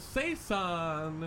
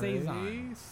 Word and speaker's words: saison: Tropical Saison. – Okay, don't say it like that. It's a Saison.